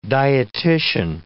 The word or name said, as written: dietician